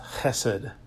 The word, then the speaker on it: chesed: Loving kindness is a translation of a Hebrew word pronounced chesed.